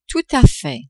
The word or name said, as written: tout a fait